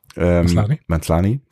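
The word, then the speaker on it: Maslany: Auch das übrigens, wo wir eben hier bei Tatjana Manzolai waren. Manzlani, danke, so wurde sie ausgesprochen.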